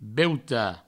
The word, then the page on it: beutat: Provençal; beuta